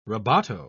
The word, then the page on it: rebato: British English